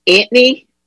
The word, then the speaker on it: Anthony: Anthony is pronounced Antony, and I know soft pretzels and the Eagles.